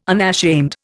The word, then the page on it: unashamed: Indian